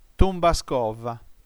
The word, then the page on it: tumba·scova: tum.ba.scò.va; [tumba'skɔva]